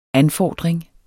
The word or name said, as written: ANFORDRINGS